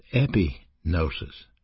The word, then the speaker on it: epignosis: There is another word for knowledge used in the Bible, and it's pronounced epignosis. And it's the word gnosis with a preposition epi in front of it, which is full knowledge.